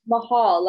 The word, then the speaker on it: Mahal: Mahal, like Mahal. – Taj Mahal. – Okay, love it. Okay, cool. – Taj Mahal, but it's pronounced like that.